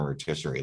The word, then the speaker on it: rotisserie: I don't even know if that matters in rotisserie leagues. Is that the right way? Am I saying that wrong? Rustisier? Rustisier? How are you supposed to say that? – It's rotisserie, but I don't know.